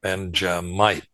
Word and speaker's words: Benjamite: A certain Jew's name is Mordecai, gives his lineage, the son of Jair, the son of Shimei, the son of Kish, a Benjaminite, Benjamite, I should say. I'm getting too many syllables in there.